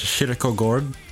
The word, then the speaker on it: Kierkegaard: The correct pronunciation is Kierkegaard, which is the Swedish way of saying it.